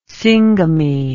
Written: syngamy

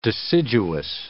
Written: deciduous

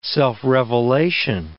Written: self-revelation

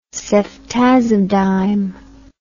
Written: ceftazidime

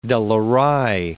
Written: De La Rey

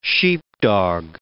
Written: sheepdog

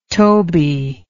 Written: Toby

